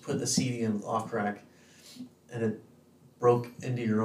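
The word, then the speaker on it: Ophcrack: I think it's actually pronounced off crack.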